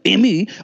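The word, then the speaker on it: Aimée: Her name is Cyrille, C-Y-R-I-L-L-E, Amy, A-I-M-E-E, with an accent on the first E. Cyrille Amy. I could say in French, Cyrille Amy.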